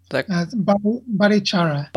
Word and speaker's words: Barichara: I'm going to say the name correctly. Baricara? – Barichara. – Barichara. Okay, thank you.